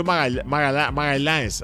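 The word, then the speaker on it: Magalhães: Mauricio Magaláes, así se pronuncia.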